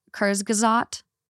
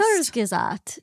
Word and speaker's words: Kurzgesagt: Do you guys ever watch, it's German, and I will butcher the name, but it's pronounced, like, Kurzgesagt.